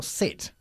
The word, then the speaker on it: sit: Bien, ojo, ten mucho cuidado con la pronunciación del verbo sit, ¿ok? – Ok, es sit. – Importante empezarlo con un sonido limpio de S y no de sh.